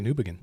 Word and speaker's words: Newbigin: That's why I know how to pronounce his name. Leslie Newbigin. – Newbigin, okay. – Yeah. – Newbigin. I stand corrected.